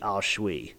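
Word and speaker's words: Aos Sí: It's spelled A-O-S-S-I. It's pronounced the Ao Shui.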